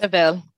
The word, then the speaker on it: Tavel: So he's building rosé that he wants to age for 5 years, like Tavel. Or is it Tavel? Help me out here. I can't pronounce that stuff. But Tavel. Tavel. Yeah, the first one.